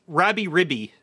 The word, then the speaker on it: Rabi-Ribi: No, it's not. It's Rabby Ribby. – Rabby Ribby. – Rabby Ribby. – Can you tell me how to spell it? Because to me, it's spelled like rabbi. Maybe I have the wrong number of... – R-A-B-I-R-I-B-I.